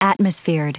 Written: atmosphered

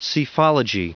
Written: psephology

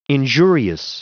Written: injurious

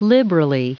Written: liberally

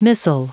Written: missile

